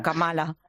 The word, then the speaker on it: Kamala: Por cierto, una cosita, Juan, que andamos siempre aquí los periodistas y todo el mundo en España, ¿cómo debemos pronunciar el nombre de Kamala Harris? ¿Kamala o Kamala? – Kamala, como Kamala. Si fuese una palabra estrújula, como si pusiésemos el acento en la Kamala.